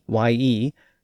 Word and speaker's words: ye: But there was also a pronoun spelled Y-E, and it was actually pronounced ye.